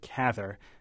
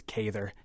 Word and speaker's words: Cather: Well, she said herself it rhymes with gather or rather, so she preferred Cather. But the only thing she couldn't tolerate was when it was Cather. She couldn't handle that, so Cather is acceptable, I think.